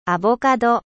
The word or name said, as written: avocat